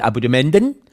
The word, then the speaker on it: Abonnementen: Und damit ganz herzlich willkommen, liebe Abonnementen. Richtig ausgesprochen, die Abonnementen.